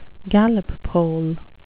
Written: Gallup poll

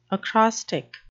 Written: acrostic

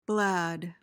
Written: blad

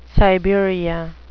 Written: Siberia

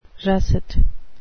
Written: russet

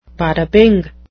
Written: bada-bing

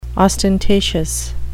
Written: ostentatious

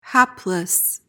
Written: hapless

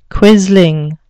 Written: quisling

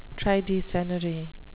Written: tridecennary

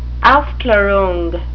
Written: aufklarung

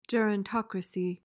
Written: gerontocracy

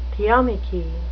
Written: theomachy

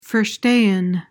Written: verstehen

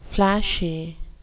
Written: plashy

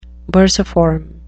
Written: bursiform

